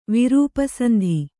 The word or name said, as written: ವಿರೂಪಸಂಧಿ